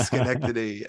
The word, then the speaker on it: Schenectady: Yeah, Schenectady? Did I say that right? – It's Schenectady.